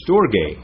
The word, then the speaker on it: storge: It's storge. You might want to write this down. English is spelled S-T-O-R-G-A-Y, storge.